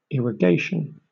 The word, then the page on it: irrigation: British English